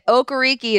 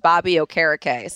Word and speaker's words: Okereke: But also Bobby Okereke is not Okereke. It is pronounced Bobby Okereke.